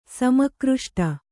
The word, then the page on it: ಸಮಾಕೃಷ್ಟ: kannada